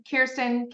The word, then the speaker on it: Kjersten: My first name, by the way, silent J in my name, Kirsten, K-J-E-R-S-T-E-N. It's a Norwegian name. Thanks, Mom and Dad, for throwing the silent J in there. And then Fossler is my last name, F-A-S-E-L-E-R.